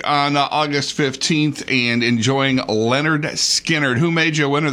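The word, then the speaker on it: Lynyrd Skynyrd: A pair of tickets to see Leonard Skinner. That's how it's pronounced.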